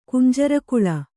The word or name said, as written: ಕುಂಜರಕುಳ